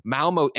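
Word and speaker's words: Malmö: Project 007 is currently in active development at IO Interactive's offices in Copenhagen, Denmark, Malmo, Sweden. Kevin, am I saying that right? What do you do when there's an umlaut over the O? – Yep.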